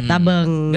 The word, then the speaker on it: tabang: Tulisan yang benar ini tabang. – Tabang. – Tabang ya. Tapi pengucapannya kalau dalam bahasa Madura AE. – Betul.